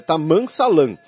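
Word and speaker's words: Tammam Salam: Desculpem, a pronúncia fica esquisita, mas é Taman Salam.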